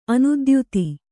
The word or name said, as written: ಅನೀತಿಯುತ